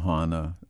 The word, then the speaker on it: Chana: Well, I call her Chona, but, you know, some people call her Hana. Chona is how I've always pronounced the name.